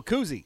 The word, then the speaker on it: koozie: What is it? Koofy? What are you? What, am I saying it wrong? The little thing that you put on it to keep it cold. – Oh, koozie.